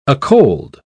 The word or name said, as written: acold